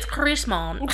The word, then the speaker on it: Chrisman: Chris Mann, that's how you spell it, like Chris the name and then Mann the man. – Chris Mann. – Chris Mann. Actually, it's Chris Mann.